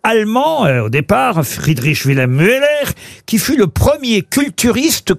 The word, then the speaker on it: Müller: Müller. – Ça s'écrit M-U-E-2-L-E-R.